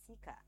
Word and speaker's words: Cika: This is the Sunny Chat Show with yours truly, Sika. My name is spelled C-I-K-A.